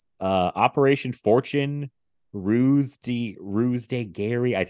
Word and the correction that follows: ruse de guerre: Ruse, I, I think Ruse de Guerre.